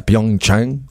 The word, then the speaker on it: Pyeongchang: Le gars, il est médaillé olympique à Pyeongchang.